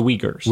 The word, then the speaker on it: Uyghurs: Tell me again how to pronounce the name of the Muslim ethnic minority that the Chinese oppress. – The Uyghurs. – Uyghurs. Okay. – Yes.